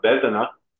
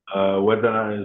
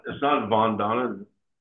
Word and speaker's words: Vedana: I wanted to come on and give you guys the gift of Vedana, Vedana is looks like it's spelled Vandana, and it's not Vandana, Vandana.